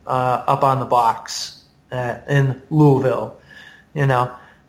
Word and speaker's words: Louisville: Well, and like that guy who, when you did your Louisville trip this year, it's Louisville, right? – Louisville, sorry. – Louisville. – I mispronounced it.